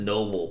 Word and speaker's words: Nobel: It's spelled, they pronounce it Noble, but I just realized it's the Nobel, like Nobel Prize.